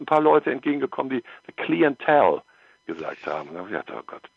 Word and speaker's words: The Clientele: Heißt es The Clientele oder heißt es The Clientele? Weißt du das? Bist, bist du da? – Also äh, auf Englisch wäre es Clientele.